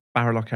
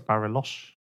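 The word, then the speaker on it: Bariloche: Well, interestingly, when we're talking about who, what, where, I mean, let's just say Barilok Airport, for example. Who is it, Barilok Airport? – I think it's Barilosh.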